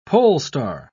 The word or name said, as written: polestar